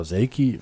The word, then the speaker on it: Selke: Aber wenn ich jetzt Selke und, oder Selki, weiß nicht, wie er richtig ausgesprochen wird, Selke, und Toussaint vergleiche. Wie wir sagen, Selke.